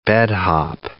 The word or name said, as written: bed-hop